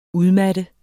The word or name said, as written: udmatte